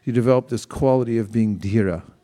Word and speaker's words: Dhira: It's spelled D-H-I-R-A, Dhira. You pronounce the H, T, like that.